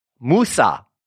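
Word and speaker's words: Musa (موسى): Musa in Arabic, which rhymes with one of my favorite Arab dishes, Lebanese dishes, kousa.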